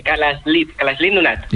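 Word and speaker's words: Kalaallit Nunaat: Nada, allí cuando juntan las dos L le ponen una S y una L. Kalalit Nunat.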